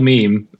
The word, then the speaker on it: MEM: So yeah, we picked MEM. It's actually Meme in Swedish. – Oh, it's called Mem? – Yeah, MEM. No, it's called Meme. It's the same, but it's spelled in MEM.